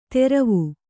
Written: ತೆರವು

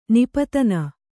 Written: ನಿಪಾತನ

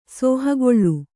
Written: ಸೋಹಗೊಳ್ಳು

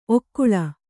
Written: ಓಕೊಳ್ಳು

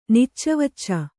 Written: ನಿಚ್ಚವಚ್ಚ

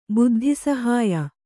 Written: ಬುದ್ಧಿಸಹಾಯ

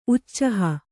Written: ಉಛಾಹ

